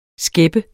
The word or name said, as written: skæppe